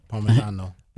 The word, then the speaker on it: Parmigiano: How do you say Parmesan again? Parmigiano.